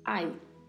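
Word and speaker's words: hay: Também é escrito no início com a letra H, que não tem som, e ao final com a letra Y, que pronuncia-se também com som de I. Ai.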